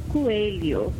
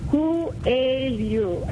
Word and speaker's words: Coelho: So his name is Paulo Coelho. Coelho. – Coelho. – So say it. But So hopefully you guys got it. So Sarah, it's Coelho.